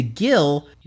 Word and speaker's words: Gil: he hands it off to Gil. – Rhymes with kill.